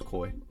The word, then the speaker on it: Coy: My first name is Coy, oh. like C-O-Y, like the fish. But I don't go by it because people pronounce it like Corey and Coey.